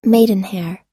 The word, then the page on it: maidenhair: er